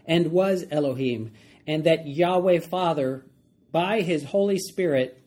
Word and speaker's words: Yahweh: And that Yod-Heh-Vav-Heh, or sometimes pronounced Yahweh or Jehovah, was Elohim, and that Yod-Heh-Vav-Heh, or Yahweh, Father, by his Holy Spirit, worked together both with and through Yeshua as one.